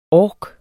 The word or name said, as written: ork